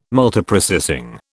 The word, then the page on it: Multiprocessing: British English